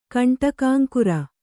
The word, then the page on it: ಕಂಟಕಾಂಕುರ: Kannada